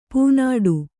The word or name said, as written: ಪೂನಾಡು